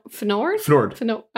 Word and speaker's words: Fnord: no, no, go on about Fjord. Fnord. – Fnord. – I have forgotten. I've already forgotten his name. I'm, I'm assuming it's Fnord. It, it, it's spelled F N O R D.